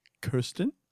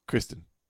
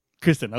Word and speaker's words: Kristen: Is it Kirsten or is it Kristen Bell? – Kristen. – Kristen, okay, good.